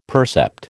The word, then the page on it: percept: American English